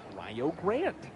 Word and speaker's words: Rio Grande: In Rio Grande, Ohio, they pronounce it Rio Grande. Check over to first. And Ogans is back in time. I learned it the hard way of how to pronounce Rio Grande.